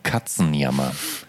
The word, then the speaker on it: Katzenjammer: Uh, why Katzenjammer. – How, how, do you pronounce it, by the way? Well, we pronounce it Katzenjammer, of course.